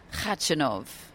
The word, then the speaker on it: Khachanov: But it's Karen Khachanov. – So I don't pronounce the K. Hence why sort of Brad Gilbert nicknames him Silent K.